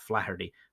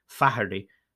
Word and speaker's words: Faherty: In 2010, 76-year-old Michael Flaherty, I never know how to pronounce those names, no, not Flaherty, Faherty, F-A-H-E-R-T-Y, Faherty, of Galway, Ireland, was found dead in his living room floor.